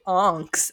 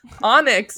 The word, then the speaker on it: Onyx: You always say Onks, and I'm like, what? – Onyx? – What is Onks? Onyx, yes.